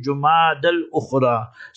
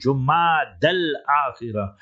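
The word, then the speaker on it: Jumada al-Akhira: And the next month, the proper way pronunciation, some people pronounce it Jumad al-Ukhra. Some people pronounce it Jumad al-Thaniya. But that's not 100% correct. The Afsah and the Asah way of pronouncing the following month is Jumad al-Akhira.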